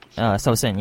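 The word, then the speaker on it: Saosin: For the band, we've been listening to a lot of music, basically from, how do you say it correctly? Is it Shaosen or Shaosen? – Shaosen, yeah.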